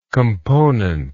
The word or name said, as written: componented